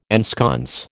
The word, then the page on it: ensconce: American English